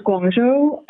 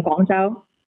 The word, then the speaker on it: Guangzhou: Um, in Mandarin is Guangzhou, uh, but I'm Cantonese, so it's um, Guangzhou.